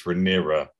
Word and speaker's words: Rhaenyra: Rhaenyra, sorry. We kept saying Rhaenyra's last time round. It's Rhaenyra.